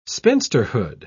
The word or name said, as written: spinsterhood